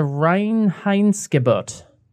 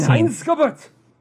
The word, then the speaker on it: Reinheitsgebot: It's the Reinheitsgebot of 1516. – Reinheitsgebot!